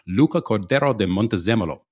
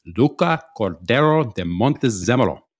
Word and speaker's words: Luca Cordero de Montezemolo: It is Luca Cordero de Montezemolo. Luca Cordero de Montezemolo.